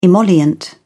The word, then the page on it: emollient: British English